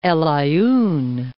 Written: Aaien, El

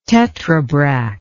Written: tetrabrach

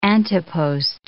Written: ante-post

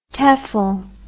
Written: tefl

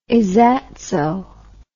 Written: izzatso

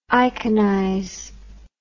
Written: iconize

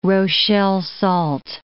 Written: Rochelle salt

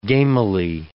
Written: gamily

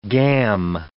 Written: gam

